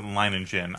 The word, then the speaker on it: Lonigan: Yeah, well, in the movie they pronounce it Lanigan. – I always thought it was Studs Lonigan.